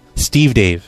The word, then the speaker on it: Stevedave: My first name's Steve Dave. – Who? – Steve Dave.